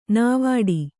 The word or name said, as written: ನಾವಾಟ